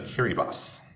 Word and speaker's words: Kiribati: Which is part of the nation of Kiribati. Where they quickly, that's the cool island pronunciation, not the way it's spelled. – Not the way it's spelled at all.